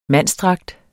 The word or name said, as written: mandsdragt